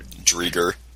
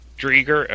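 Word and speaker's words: Driedger: Chris, Drieger. – Drieger, okay. I always mispronounce that.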